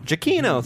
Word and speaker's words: Giacchino: Giacchino. Thank you. It Oh my God. – Actually, I think it's pronounced Giacchino. – Oh, you do a little, and there's an accent at the end.